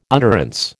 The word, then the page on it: utterance: American English